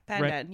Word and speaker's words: pandan: And the first one is the Singapore pie that has pandan in it, which am I saying that? – Yeah. Pandan. Yeah. – Pandan.